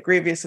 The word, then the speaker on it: Grevioux: And yeah, and I'll point out to the audience that Grevioux is with an X, not an S at the end. – Well, yeah. Spelled with an X sounds like an S. – Yes. – Yeah, so that... the way I put it, it rhymes with previous.